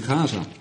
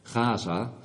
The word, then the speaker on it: Gaza: Gaza, dat betekent eigenlijk gewoon sterkte. Die G, die spreek je eigenlijk niet uit in het Hebreeuws. Dat is een klank die wij niet zo goed kennen.